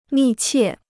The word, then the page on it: 密切: mì qiè